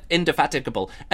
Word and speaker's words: Indefatigable: And I know it's called, I know it's how it's pronounced, because that's how it's pronounced. I can't even say it now that I've been saying it wrong. I can't say it. Indefatigable.